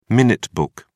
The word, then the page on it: minute book: British English